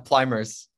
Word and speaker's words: Pluymers: Did I say your last name right? Would you say it? – Yeah, Pluymers.